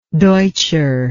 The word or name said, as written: deutscher